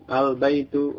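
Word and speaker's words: Al-baitu (البيت): Contoh al baitu, Ad-diku, al-baitu rumah. Ad-diku ayam jantan. Jadi sesuai, benar. Al-baitu, lamnya jelas. Karena ba itu tadi komariah. Kemudian ad-diku, lamnya masuk ke dal.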